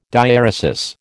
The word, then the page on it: diaeresis: American English